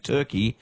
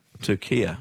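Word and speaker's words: Türkiye: But in yesterday's Socceroos updates, you kept saying Turkey and not – Turkia. – Turkia, what's doing? I'd never heard of Turkia, Ryan, is my honest answer. – Yeah. – Never, ever, ever heard of it pronounced that way.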